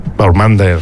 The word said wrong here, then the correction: Baumgartner: Baumgartner, sí.